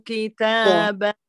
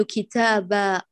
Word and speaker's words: كتابا: لا نقول كتابة كتابة هذا لحن جليل.